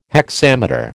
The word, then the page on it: hexameter: American English